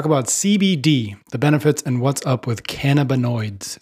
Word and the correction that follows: cannabinoids: Did I pronounce that correctly? Cannabinoids? – Cannabinoids. – Put the emphasis on the wrong syllable.